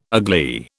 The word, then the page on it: agley: British English